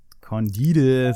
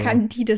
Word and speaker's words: Candide: Candide? Candide, so hätte ich es auch aussprechen können gerade noch. Der so. Candide, so wie Candida, nur ohne R hinten.